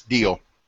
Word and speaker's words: Diehl: But this Autumn Evening from Corning and did we settle on how to say this? D-I-E-H-L? – Yeah, it's Deal.